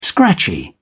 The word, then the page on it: scratchy: British English